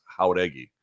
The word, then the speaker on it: Jauregui: I must have had nearly 20 DMs or texts from people being like, yeah, you're pronouncing that wrong. It's Jauregui.